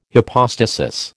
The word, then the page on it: hypostasis: American English